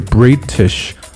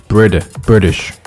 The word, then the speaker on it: British: British，注意这个的音变啊，不读的不是 British，而是 British。首先呢，非常快的弹过去，British。